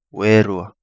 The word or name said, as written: Werua